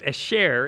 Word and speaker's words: asher: From that one time from Barak, now it's asher is how you say that.